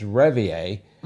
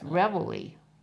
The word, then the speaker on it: reveille: And I'm like, okay, it's not Ratatouille, it's Réveillé. – No, it's Reveille. – Well, I pronounce it Réveillé because I think that's more. But yeah, how do you pronounce it? – Reveille.